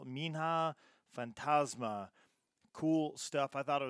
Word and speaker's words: Minha Fantasma: Minha Fantasma.